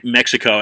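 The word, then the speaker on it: México: Well, with that, right, the Spaniards originally wrote Mexico as M-E-J-I-C-O. They pronounced it Mexico. And that's why the Mexicans pronounce their country Mexico. But the X, the X, is actually a sh sound. So it literally should be Mexico because of the Aztec tribes, the Mexicas. Anyway, that's beside the point.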